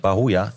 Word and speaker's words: Bahoya: Denn dann Deal bei Bauer, ba, wie hast du ihn ausgesprochen? Bauer. Bauer. Bauer. Hm? Bahoa. Ich hätte Bahoa. Bahoa.